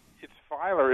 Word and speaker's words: Feiler: It's relief backwards, and you're probably. Uh, I know of the generation that remembers the old Rolaids commercial about how you spell relief, and we used to always say, "You spell it backwards, it's Feiler."